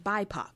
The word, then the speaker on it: BIPOC: Oh, it's pronounced BIPOC.